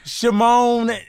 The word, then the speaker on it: Shimon: His name rhymes with Macon. Shimon rhymes with Macon.